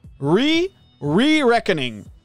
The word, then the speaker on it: Re-Reckoning: Zeg ik dat goed? Re-Re-Reckoning. Ja. Het was zeg maar Kingdoms of Elmer Reckoning. En nu is het Re... Rec oh nee, ik zei het Re-Reckoning. Dat is de naam.